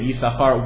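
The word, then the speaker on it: Yissachar: And all the Chachmei Masora of the Rishonim and Achronim are basically Sebe Pe'echa, that you follow, you read it Yis-Sachar, one sin is silent, it's a Kreuk sin.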